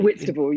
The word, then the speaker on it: Whitstable: What are the, you live in, is it Whitstable? Am I saying that right? – Whitstable, yeah.